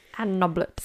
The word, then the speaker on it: Noblett: That's how it says it's pronounced, but I'm already getting the giggles. – Anne Noblett.